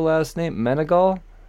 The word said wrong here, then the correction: Meneghel: Meneghel, and it's Elisa Meneghel. – Elisa, I butchered the first name. Elisa Meneghel. All right, gotcha.